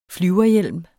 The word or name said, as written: flyverhjelm